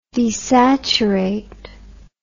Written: desaturation

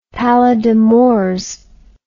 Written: pallida mors

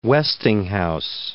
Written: Westinghouse

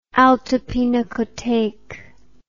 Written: altepinakothek

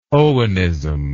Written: Owenism

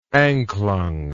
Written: anklung

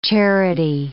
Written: charity

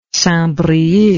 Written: St Brieuc

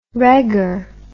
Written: regur

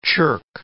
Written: chirk

